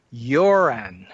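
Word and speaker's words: Göran: As a matter of fact, I've had some communication with this gentleman from Sweden, and it's pronounced, and I'm going to butcher this, but from what I understand, it's pronounced a little more like Joran.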